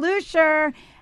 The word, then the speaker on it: Luscher: Which is Rachel, am I pronouncing it correctly, Lusher? – Rachel Lusher.